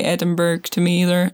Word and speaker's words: Edinburgh: You're in like Edinburgh. – Mm-hmm. – Did I say that correctly? – You actually pronounced it correctly. A lot of people can't.